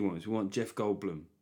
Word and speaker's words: Goldblum: Yeah, and I am saying Goldblum because I found out recently that's how it's pronounced.